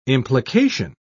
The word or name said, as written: implication